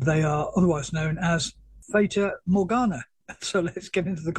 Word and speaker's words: Fata Morgana: So it's called Fata Morgana.